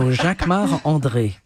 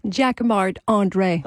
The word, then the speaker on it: Jacquemart-André: You say it like André. – A Frenchman. Jacquemart. – Of course, because I'm French. So Jacquemart-André.